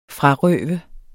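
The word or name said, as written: FRARØVES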